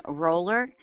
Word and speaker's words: Roller: Hi, my name is Elaine Roller, and I just received my transit guide, but it's for 2017.